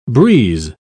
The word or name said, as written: breeze